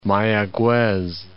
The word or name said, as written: Mayaguez